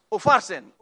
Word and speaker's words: Upharsin: And the handwriting was like M E N E, mene, mene, tackle, u, u, farnes. Did I say it right? Upharsin, upharsin.